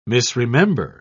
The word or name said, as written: misremember